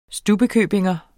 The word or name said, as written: stubbekøbingenser